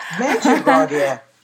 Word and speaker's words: Medjugorje: Visions of Mediocre. You help me, please. – Medjugorje. Visions of Medjugorje.